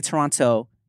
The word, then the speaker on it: Toronto: Well, look, speaking of Drake, speaking of Toronto slang, I learned a long time ago from the Toronto homies not to pronounce the second T. Yeah. Because then they'll know you're not from there. – Yeah. – So you don't say Toronto, you say Toronto.